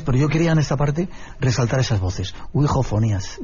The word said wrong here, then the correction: Ouijafonías: Ouijo, no lo digo bien, ¿cómo se dice? – Ouijafonías. – Ouijafonías.